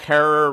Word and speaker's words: Herer: You just have to find them on Deadlot, and they tell you the whole Jack Herer rhymes with terror book from start to finish.